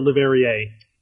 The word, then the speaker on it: Le Verrier: Le Verrier or Le Verrier? – Le Verrier. That's sure? – how I'm saying it. – Rhymes with Derriere.